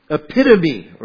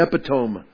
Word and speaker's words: epitome: the epitome, or if you say it wrong, the epitome. I've said it wrong a lot of times.